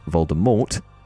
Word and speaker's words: Voldemort: But considering by 2015, all 8 movies had also been released in which Voldemort was pronounced Voldemort, I say, what gives? As Rowling was involved in the making of the films, she would have had ample opportunity to correct this mispronunciation, right? So to casually drop in years later that we've all been saying Voldemort wrong for decades seems to break what was believed to be canon for years.